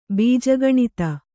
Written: ಬೀಜಗಣಿತ

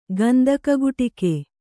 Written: ಗಂದಕಗುಟಿಕೆ

